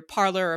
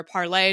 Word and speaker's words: Parler: a site called either Parler or Parlay, depending on how you pronounce it,